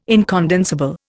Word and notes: incondensable: Indian

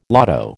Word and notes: lotto: American English

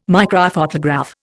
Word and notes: microphotograph: Indian

